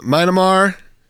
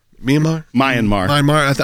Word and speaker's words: Myanmar: You have Myanmar. – Myanmar.